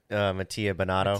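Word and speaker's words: Mattia Binotto: How do you pronounce that guy's name? The manager, Mattia? Is that it? – Mattia Bonato.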